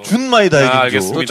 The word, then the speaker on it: 준마이 다이긴조: 준마이 다이긴조. – 준을 너 자꾸 네. 모음을 이상하게 오로 발음해 너. – 그래요, 네. 준이다 너. – 네. 알았어. – 준마이 다이긴조.